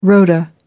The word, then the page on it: rota: American English